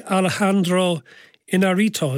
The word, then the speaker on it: Alejandro Iñárritu: Okay, it's also directed by Alejandro Iñárritu, is that how I pronounce it? – Yes, that's him, yeah.